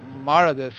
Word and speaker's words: Marauders: mas agora que você já explicou aí que é Marauders, que é Marauders em inglês, acho que não tem nem mais por que fazer essa pergunta.